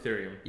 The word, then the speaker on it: Ethereum: Ethereum.